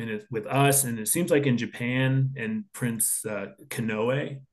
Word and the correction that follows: Konoe: is that how you say it? Like a settlement was very, very close to happening that might have prevented war. – Well, Konoe, they say.